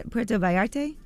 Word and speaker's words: Puerto Vallarta: No, you know, Mexico is La Puerto Vallarta. Did I say that right? – Puerto Vallarta? – Yes, that's the place.